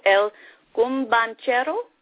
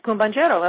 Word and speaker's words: El Cumbanchero: And I'm going to play one that I'm going to try to pronounce right now, and I believe it's El Cumbanchero. – That's it, Cumbanchero.